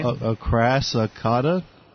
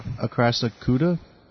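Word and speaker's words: Acrassicauda: I'm going to spell it. A-C-R-A-S-S. It's got ass in it. I-C-A-U-D-A. Yeah, spell that, or pronounce that, bastard. Anyway. – Acrasicata? – Acrasicata. – Acrasicuda?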